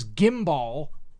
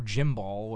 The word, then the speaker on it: gimbal: They were called Flyer 1, F-L-I-R 1. The second one was Gimbal, G-I-M-B-A-L, or Jimbal, whatever you want to, however you want to do that. What is sure. – this, Mr. Sword? How would you pronounce that? – Oh. Spell it again? – G-I-M-B-A-L. It's like gumball, but with an I.